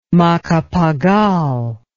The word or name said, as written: macapagal